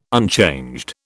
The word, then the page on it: unchanged: British English